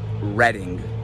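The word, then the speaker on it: Reading: Surely we can all pronounce this, right? Well, only if you pronounced it Redding.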